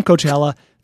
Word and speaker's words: Coachella: He texted me and said, having lived in the Coachella Valley and speaking with old people from Coachella, the A is silent. So even the old-timers there know that it's Coachella.